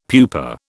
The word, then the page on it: pupa: British English